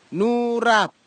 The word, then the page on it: nurap: kwamera; Tanna